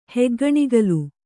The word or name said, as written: ಹೆಗ್ಗಣಿಗಲು